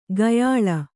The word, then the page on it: ಕೊಯ್ಯಾಳ್: koyyāḷ